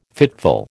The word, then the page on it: fitful: American English